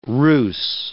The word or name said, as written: rhus